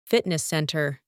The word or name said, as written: fitness centre